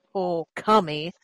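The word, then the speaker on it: Ōkami: And my husband looked at the kanji and he's like, yeah, the kanji says spirit god, but it's pronounced okami.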